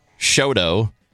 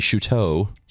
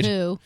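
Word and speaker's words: Chouteau: And Shoto should be Shuteau, right? – Shuteau.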